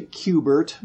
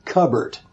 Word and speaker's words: Q*bert: And somehow that morphed into Q Bert, but it was spelled like C-U-B-E-R-T. And I think Ron Waxman supposedly said, well, that's no good because people can pronounce it Cubbert instead of Q-Bert. So that's when we went with the Q with the asterisk, B-E-R-T.